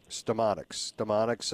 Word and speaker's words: StemoniX: I think it's called, I can't pronounce it, StemoniX, yes. – StemoniX, a subsidiary of Viant Bio.